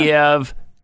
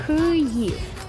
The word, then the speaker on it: Kyiv: Kyiv. And it's not Kiev. Kyiv is Russian spelling and pronunciation. Kyiv. – Kyiv. – That's how you say it in Ukrainian.